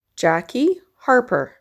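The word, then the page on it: Jackie Harper: English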